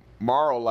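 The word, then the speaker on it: Marle: Is it Marl or Marley? – Marl, I assume. – I always said Marl. – I did too. – One syllable.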